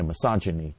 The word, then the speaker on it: misogyny: Actually, it's misogyny. It's misogyny. I don't think I pronounced it right. Yeah, misogyny. Even though it's with a G, I think you pronounce it like a J, misogyny, and were uniquely anti-women, while women elsewhere were equal and had rights.